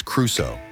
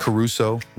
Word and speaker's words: Crusoe: It's Crusoe. Rhymes with do so. Not Caruso, rhymes with LaRusso, like the Karate Kid.